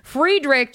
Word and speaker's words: Friedrich: To go from Friedrich von Trapp, because I guess it was Friedrich if you do the German pronunciation of it, Friedrich to, you know, Spider-Man, that's a career arc right there.